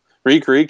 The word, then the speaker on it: Reek: Reek, it rhymes with Meek.